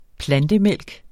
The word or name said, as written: plantemælk